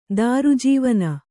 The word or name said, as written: ದಾರುಜೀವನ